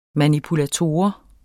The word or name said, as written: manipulator